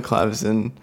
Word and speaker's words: clavecin: um, Tom Hardy is in it, and Air is in the drawing room on the clavecin, on the clavecin, which is the French French word for harp, harpsichord.